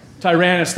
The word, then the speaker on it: Tyrannus: We read about when he was in Ephesus in the book of Acts, and he rented out the school of, starts with T, rhymes with Nyranus, school of Tyrannus, thank you.